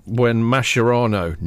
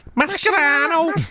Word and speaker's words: Mascherano: I think it was when Mascherano, known as the rat. – That's what they used to call him in Liverpool. – Mascherano. No, they pronounce the C. – Mascherano.